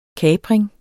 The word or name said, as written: kapring